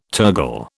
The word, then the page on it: tergal: British English